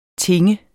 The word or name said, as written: tinge